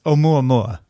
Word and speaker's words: Oumuamua: It's actually not hard at all. It's Oumuamua. – Yeah, it is. But it could be Oumuamua because it's Hawaiian and every syllable has a vowel.